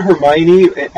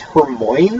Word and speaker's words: Hermione: I thought Hermione, as like before I heard it out loud properly, I thought it was Hermoine.